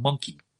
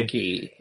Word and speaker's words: Munki: The band now included former Lush bassist Phil King, and they then recorded the 1998 album called Monkey. Okay. – Monkey. – It's spelled M-U-N-K-I.